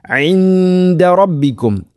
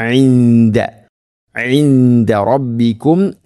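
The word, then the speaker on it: ഇൻദ റബ്ബിക്കും (عِنْدَ رَبِّكُمْ): ഇൻദ റബ്ബിക്കും ഇഖ്ഫാ ബിഗുന്ന, ഇവിടെയും നൂനിനെ അവ്യക്തമാക്കി മണിക്കണം. ഇൻദ ഇൻദ റബ്ബിക്കും അഫല തഅഖിലൂൻ തഅഖിലൂൻ, ഖാഫിന്റെ ഉച്ചാരണം പ്രത്യേകം ശ്രദ്ധിക്കുക.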